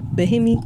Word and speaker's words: Bimini: So we departed from Norfolk, and we stopped at Celebration Key Bahamas first, and then we went to, how do you say it? Bimini? Bimini. – Okay. – Bimini, Bahamas. Yeah.